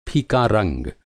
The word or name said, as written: फीका